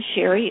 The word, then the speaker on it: Sharry: If people want to go on YouTube and look under my name, Sharry Edwards,